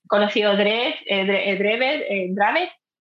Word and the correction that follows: Dravet: siempre me creo que es, creo que es Dravet. Exactamente. No sé cómo se pronuncia, pero creo que es Dravet.